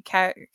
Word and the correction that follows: Calafiore: How do you say his last name? – Calafiore. – Yes, who is the big winner of Big Brother All-Stars.